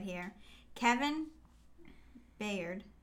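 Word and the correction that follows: Byard: Bard. – How many times am I going to get his name wrong? – I don't know. – Kevin Bard.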